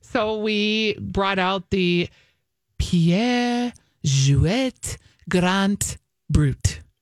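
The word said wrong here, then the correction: Perrier-Jouët: Okay. Perrier Jouet? – Thank you.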